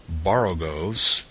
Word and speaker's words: borogoves: Again, the first O in Borogoves is pronounced like the O in borrow. I have heard people try to give it the sound of the O in worry, such as human perversity.